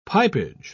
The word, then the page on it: pipage: British English